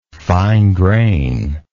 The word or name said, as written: fine grain